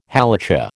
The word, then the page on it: Halacha: American English